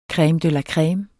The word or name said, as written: creme